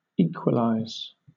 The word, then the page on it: equalize: British English